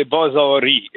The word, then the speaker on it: Bazari: Et vous avez, et c'est là la question fondamentale, est-ce que les Bazari, et vous remarquez que je le prononce en farsi parce que mmh.